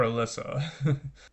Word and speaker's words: Elisa: That was The Garden by Elisa Thorne, ELISA, or you can pronounce it Elisa as well, or Alyssa.